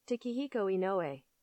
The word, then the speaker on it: Takehiko Inoue: Takehiko Inoue.